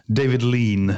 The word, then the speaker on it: David Lean: Du får nog uh, uttala det rätt en gång. – Uh, Okej, okay. vad heter han då som eh, regisserade just Lawrence of Arabia? David Lean. David Lean, okej.